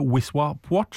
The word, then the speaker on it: Wisła Płock: Han har spilt for Krakowia i dag borte mot Wisła Płock, eller Wisła Płock, som det uttales.